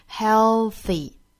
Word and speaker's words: healthy: 好的，那么我们来看 healthy, 我们还要注意这个 th 的发音呢是一个咬舌的 th, 注意是一个清辅音 th, healthy, 并且它的尾音我们看到了是一个 e 的发音，并不是 e, 不是 healthy, 而是 healthy, 嘴角呢两边裂开形成一个紧张的 e 的发音 healthy。